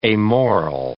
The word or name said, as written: amoral